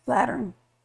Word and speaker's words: Lateran: By the Lateran? Lateran? How do you say that? – Lateran.